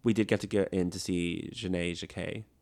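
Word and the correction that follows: Janey Jacké: Janie Jacquet.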